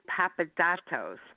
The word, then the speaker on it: Papadatos: That's Marcos Papadatos, and I'll spell it as well. M-A-R-K-O-S P-A-P-A-D-A-T-O-S. Because I had to really work on the pronunciation. It's great. – A little tricky.